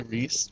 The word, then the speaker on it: Reese: Oh, and is it Ivan Reese or Ivan Rice? I've never been sure about how to say his last name. – I always say Reese.